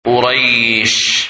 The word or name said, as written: Quraysh